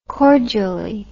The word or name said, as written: cordially